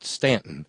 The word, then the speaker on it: Staunton: It's pronounced Stanton, but it's spelled S-T-A-U-N-T-O-N, which would seem like Staunton, but it's Stanton.